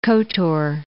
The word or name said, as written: Kotor